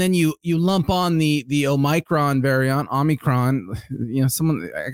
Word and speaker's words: Omicron: Omicron. You got to roll your R when you say it.